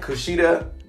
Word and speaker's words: Kushida: Kushida, the better pronunciation of his name.